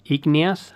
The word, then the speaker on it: Igneous: That is why I was trying to pronounce igneous, how was it? – Igneous.